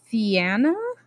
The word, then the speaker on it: Fianna: She is found, and she's adopted into a band, and I think this word is pronounced Fianna, F-I-A-N-N-A.